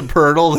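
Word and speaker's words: Poeltl: Players, yeah, because there's a guy named Jakob Purdle, but it's actually spelled like P-O-L-T-L or something like that.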